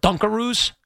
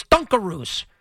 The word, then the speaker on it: Dunkaroos: Just like I was saying dungaroos instead of dunkaroos. Nobody corrected me. All these years I'm saying dungaroos, it's dunkaroos. Which actually makes sense because you dunk the roo.